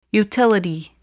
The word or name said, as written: utility